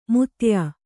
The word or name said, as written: ಮುತ್ತಯ್ಯ